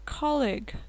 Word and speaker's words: colleague: Colleague, коллега, colleague. На выдохе произносится, чтобы к немножко стало такой воздушно, с придыхания. Colleague.